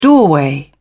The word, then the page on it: doorway: British English